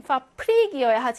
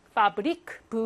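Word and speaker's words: Fabrik: 얘는 파프릭이어야 하지만 파브릭 브로 발음하고요.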